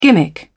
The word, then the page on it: gimmick: British English